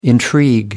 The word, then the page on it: intrigue: American English